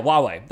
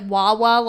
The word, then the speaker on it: Huawei: Huawei. That's how you pronounce that. It's spelled like Huawei. – Oh, I thought you meant like Wawa, like the gas station.